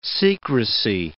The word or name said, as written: secrecy